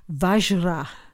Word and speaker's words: Vajra: The first syllable is Hri, H-R-I-H, Hri. Padman, P-A-D-M-A-N. Tatro, T-A-T-R-O. The next one took me a while to get. Vajra, V-A-J-R-A. Krodha, K-R-O-D-H-A. Haya Griva, we've done. Hulu Hulu, exactly like it sounds, H-U-L-U. Hulu Hulu. Hum, H-U-M. Te, P-H-E-T. So you've got Om, Hri, Padman, Tatro, Vajra, Krodha, Haya Griva, Hulu, Hulu, Hum, Te.